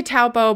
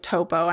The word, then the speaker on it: Taupo: So the second one is Taupo, which I think I say Taupo, but like I think the correct pronunciation is more like Topo.